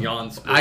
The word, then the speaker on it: JanSport: I thought it was Jan Sport. – I did too. – I was going to say Jan Sport. – I just found out today it was Yon Sport.